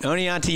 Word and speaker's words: Oneonta: You managed the 1995 Oneonta, did I say that right? – Yep. – Oneonta Yankees, short season A in Oneonta, New York.